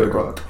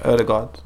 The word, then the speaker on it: Ødegaard: I can't pronounce his name, so I don't want to say Ødegaard. – Yeah, Ødegaard.